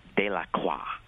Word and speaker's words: De La Croix: It's about an African-American, and the correct pronunciation is De La Croix.